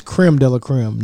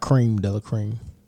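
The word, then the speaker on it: crème de la crème: And it's creme de la creme, not cream de la creme.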